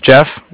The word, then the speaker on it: Jeff: My name's Jeff, J-E-F-F. – Okay. – And I'm calling from Bank of Cardiff. Cardiff is spelled C-A-R, as in car, and then D-I-F-F, as in Frank.